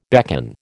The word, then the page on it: beckon: American English